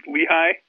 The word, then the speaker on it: Lehigh: Well, a school that I actually had to look up named Lehigh. I think I'm pronouncing it correctly. – Yes, you are.